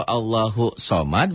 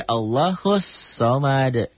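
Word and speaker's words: Allahus somad (ٱللَّهُ ٱلصَّمَدُ): Kalau Allahu somad berarti somadnya jadi nakiroh. Sedangkan di sini ma'rifah. Bacanya jangan Allahu somad tapi Allahus somad. Karena di situ ada alif lam samsiah nyamber ke sod.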